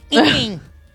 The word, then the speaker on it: Eaton: Eaton. – Well, that's how you say it, Eaton.